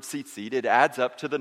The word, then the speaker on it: tzitzit: And so these tassels, the Hebrew name for tassel is tzitzit. Everybody say tzitzit. It's kind of fun to say, isn't it? It's spelled with a T and a Z in succession. Tzitzit.